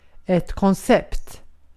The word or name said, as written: koncept